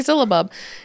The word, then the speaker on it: syllabub: I'm assuming syllabub. S-Y-L-L-A-B-U-B. – Yeah. Syllabub. – Syllabub.